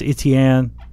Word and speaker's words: Etienne: Travis Etienne.